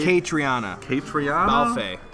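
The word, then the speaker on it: Caitríona Balfe: Catriona Balfe.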